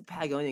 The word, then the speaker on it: Patagonia: Like Patagonia, I think that's how you say it. – Yeah, Patagonia.